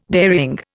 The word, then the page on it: dairying: Indian